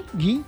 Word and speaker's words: ghee: Ghee. – ¿Ghee? – Uh-huh.